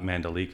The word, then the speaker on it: Mandalika: So we're going to talk about World Superbike Round 2 from Mandalika. I guess that's the way they pronounce it. Not Mandalika like we've been saying.